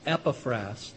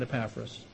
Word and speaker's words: Epaphras: In speaking of one of his fellow workers, Paul, who wrote this, is writing about a fellow named Epaphras. That's the way you pronounce that, not Epaphras.